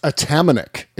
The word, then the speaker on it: Atamanuik: I think the last name is pronounced Atamanuik. It's Tony Atamanuik.